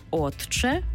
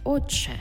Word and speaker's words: отче: «Ти» і «чи» і утворюється в нас подовжений звук «чи». Тобто пишемо «отче», а вимовляємо «отче».